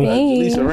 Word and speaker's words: Jaleesa: Yo, Jaleesa, right? – Yes. – I feel like I'm saying it wrong. – No, you're fine. You're fine.